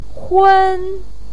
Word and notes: hun1: pinyin

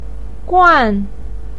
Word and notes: guan: 4

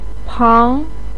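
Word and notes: pang2: tone 2

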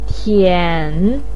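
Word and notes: tian: tone 3

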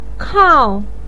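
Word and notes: kao: tone 4

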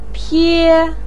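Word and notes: pie1: tone 1

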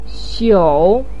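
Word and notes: xiu: tone 3

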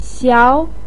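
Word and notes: xiao: tone 2

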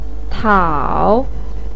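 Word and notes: tiao: tone 3

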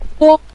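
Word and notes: bo: tone 5

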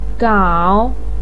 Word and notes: gao3: pinyin

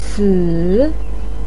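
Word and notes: si: tone 3

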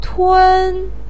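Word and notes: tun: tone 1

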